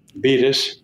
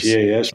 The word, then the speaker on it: Bettas: Bettis, B-E-T-T-A-S. Bettis.